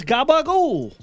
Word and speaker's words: gabagool: You pronounce it properly. It's gabagool settle, Super side.